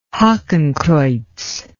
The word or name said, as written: hakenkreuz